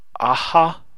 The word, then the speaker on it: Aja: Hawkeye is also fun, and I should mention that that's by Matt Fraction and David Aha or Aja. I believe it's Aha's how it's pronounced